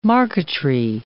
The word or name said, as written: marquetry